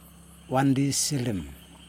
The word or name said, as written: wandisilim